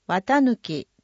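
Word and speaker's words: 四月朔日: 中でも 4 月 1 日、 1 日は八朔の朔に日と書く、と書いて、綿抜きと読む名字に出会った時の衝撃が、半世紀にわたる研究への第一歩となりました。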